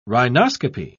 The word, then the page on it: rhinoscopy: British English